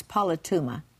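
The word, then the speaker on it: politeuma: But I would say it's P-O-L-I-T-E-U-M-A. I would say it's polituma. And you can kind of hear the words, a city is Annapolis, polis, metropolis, polis. And actually this word,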